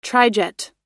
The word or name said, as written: trijet